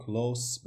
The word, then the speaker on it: close: وقتی که میایم به جای ز آوای س رو تلفظ میکنیم و خارج میکنیم close به معنای نزدیک